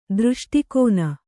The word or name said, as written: ದೃಷ್ಟಿಕೋನ